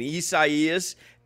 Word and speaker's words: Isaias: I've practiced pronouncing this hurricane, Isaias. And then yesterday I called it Hurricane Isaiah. I in the moment I looked down at my notes, it became Isaiah.